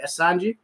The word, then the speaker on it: Assange: So Julian, I don't know if I'm pronouncing this man's right, Assange.